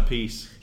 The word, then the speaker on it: Appice: Carmine a piece. – Yeah, which one does say a piece? – It's Carmine.